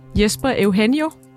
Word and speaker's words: Jesper Eugenio: Og så vil jeg gerne sige velkommen til dig, Jesper Eugenio. – Goddag. – Udtaler jeg dit navn rigtigt? – Ja, det er meget tæt på.